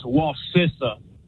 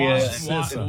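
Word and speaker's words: Wacissa: It's Wasissa. I grew up in that area. – Wasissa. – Wasissa. – Like sister. Wasissa. Hey, my Wasissa.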